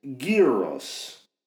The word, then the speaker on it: gyros: I'm a big fan of Greek gyros, but someone I trust on such issues told me 20 years ago that they're properly pronounced gyros.